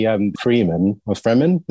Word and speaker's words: Fremen: So when it comes to the Fremen, is that how you pronounce it? – Yeah.